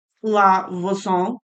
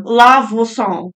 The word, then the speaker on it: La Voisin: Except for maybe the witch, because I did Google how to pronounce her name. La Voisin. – You're so... – I love that for you. Yeah. Try and do that. – It's a really great... – Kind of like croissant.